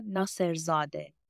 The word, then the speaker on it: Nasserzadeh: So my full name is Sara Nasserzadeh.